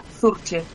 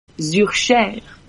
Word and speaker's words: Zurcher: pues mira, en español, digamos, sería Zurcher, tal cual está escrito. – Zurcher. – Ahí tal cual. – ¿Y si no es en español? – Pues mira, claro, depende a dónde nos vamos. En francés, que eu son da parte, bueno, son de la parte francesa de Suiza, de Ginebra, Zurcher.